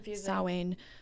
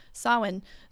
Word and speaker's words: Samhain: It's spelled Samhain. A lot of people don't know that it's pronounced Samhain. Samhain.